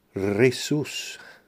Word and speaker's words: Risus: And as for a pronunciation, I didn't realise it's a Latin word meaning laughter, and it's pronounced Risus.